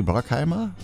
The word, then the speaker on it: Bruckheimer: Ich glaube, das wird ja amerikanisch, da wird das U wie A ausgesprochen. Ne? Jerry Bruckheimer, würde ich mal so sagen.